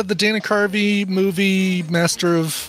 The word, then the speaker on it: Dana Carvey: Oh, of course, I remember Dana Carvey. Can't even say his name, so forget him. – Danny Curry.